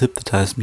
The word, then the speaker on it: Hypnotize: Das war so ein bisschen sein Aufatmen und genau, und da waren auch halt Tracks drauf wie Hypnize. Ich kann es ja aussprechen. Hypnize. Oh Mann, du wisst schon, Biggie, can't you see? Sometimes the world has hypnotized me.